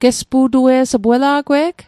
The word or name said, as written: gespu'tuet